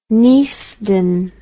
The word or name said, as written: neasden